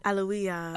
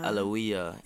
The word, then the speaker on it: Alewya: Alawiya. – Alawiya. A-L-E-W-Y-A.